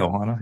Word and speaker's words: ohana: I know what what I'm gonna oh the ohana culture or whatever you know is that is that how you say it like yeah ohana yeah